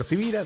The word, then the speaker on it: Sivira: Mi nombre es Carlos Javier Sivira.